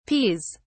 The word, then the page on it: peas: British English